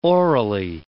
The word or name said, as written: orally